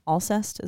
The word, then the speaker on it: Alcest: Alcest, is that how you pronounce her name? – I don't know. Have you heard that? – It is. – Alcest? – A-L-C-E-S-T.